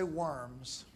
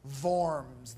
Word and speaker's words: Worms: Don't say worms, Worms. The German W's are always pronounced with V's, right?